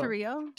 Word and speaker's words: Tarrio: T-A-R-R-I-O. – Oh, Tarrio? – Tarrio.